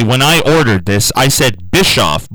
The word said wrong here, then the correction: Biscoff: Blizzard, and they corrected me and said Biscoff.